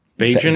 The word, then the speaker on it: Bagent: How do you sp- pronounce that? Bagent. – Bajan.